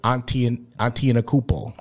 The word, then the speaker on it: Antetokounmpo: And I just said his name because I've been practicing, and now I finally know how to say it right. – Mm. – Giannis Antetokounmpo.